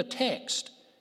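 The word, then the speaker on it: te (τε): There's a Greek word in the text.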